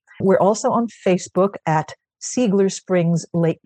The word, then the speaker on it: Seigler: And for those of you of German extraction, I know it's supposed to be pronounced Ziegler, but out here, this is the way they pronounce it.